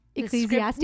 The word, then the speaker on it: Ecclesiastes: Yeah, and I'm glad you pronounced that right, because I feel like I never pronounce Ecclesiastes.